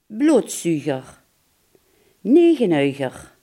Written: bloedzuiger

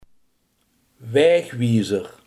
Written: wegwijzer

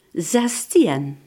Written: zestien

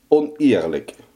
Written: oneerlijk

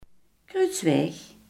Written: kruisweg

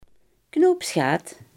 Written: knoopsgat

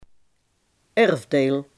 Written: erfdeel

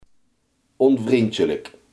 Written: onvriendelijk

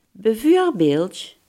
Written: bijvoorbeeld

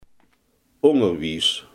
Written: onderwijs

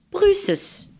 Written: duitsland

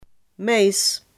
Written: mais

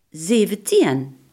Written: zeventien

